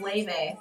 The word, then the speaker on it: Laufey: Luffy? Luffy? – Oh, wait. It's actually pronounced Leve. – Leve? – Yes, but it is spelled L-A-U-F-E-Y, but it says pronounced Leve.